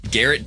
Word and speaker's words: Garrett: Garrett does rhyme with Ferret. – It sure does. – It also rhymes with Jarrett. You guys both say Miles Garrett? – Yes.